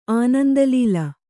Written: ಆನಂದಲೀಲ

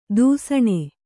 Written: ದೂಸಣೆ

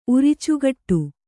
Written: ಉರಿಚುಗಟ್ಟು

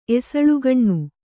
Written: ಎಸಳುಗಣ್ಣು

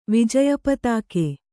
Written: ವಿಜಯಪತಾಕೆ

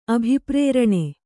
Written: ಅಭಿಪ್ರೇರಣೆ

